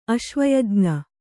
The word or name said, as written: ಅಶ್ವಯಜ್ಞ